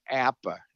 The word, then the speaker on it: APBA: Anyway, it's in that brochure that they explained it's pronounced APA, not APBA.